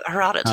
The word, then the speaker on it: Herodotus: Was it like Herodotus? – Yeah, Herodotus.